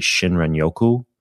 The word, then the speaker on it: Shinrin-yoku: And how this concept of forest bathing, there's even a fancy Japanese name for it called, I believe it is Shinrin-yoku.